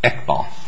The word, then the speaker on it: Iqbal: Now, Chapman said, and rightly so, that under the Supreme Court's recent decision, and I still don't know how to pronounce it, Iqbal. – Thank you.